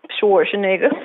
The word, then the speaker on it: Schwarzenegger: Am I saying it right? – Schwarzenegger. Mm-hmm.